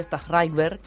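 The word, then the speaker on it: Sztajnszrajber: Bueno, acá tenemos como una pequeña cita, una pequeña explicación tal vez, de Darío Stas-Reichberg, espero estarlo pronunciando bien. – Muy bien.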